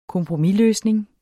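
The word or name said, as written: KOMPROMISLØSNING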